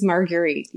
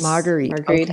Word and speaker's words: Marguerite: But boy, Margaret Henry. Am I even pronouncing her name correctly or is it Marguerite? – Very close. It is Marguerite, yes. – Marguerite.